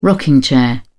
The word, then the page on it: rocking chair: British English